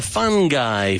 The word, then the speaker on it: fungi: These all come from the fungi family. Okay. Like a person who's fun is a fun guy. That's the pronunciation.